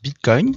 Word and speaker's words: Bitcoin: Alors ça se prononce Bitcoin, Bitcoin.